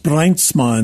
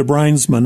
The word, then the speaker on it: Bruinsma: Old Dutch name, and in Dutch it's pronounced Bruinsma, and so Bruinsma. We Americanized that into Bruinsma.